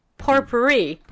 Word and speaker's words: potpourri: You mean potpourri? – Potpourri.